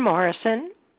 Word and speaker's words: Morrison: Catherine Morrison.